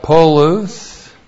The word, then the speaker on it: polus: And then we have polus. P-O-L-U-S, polus.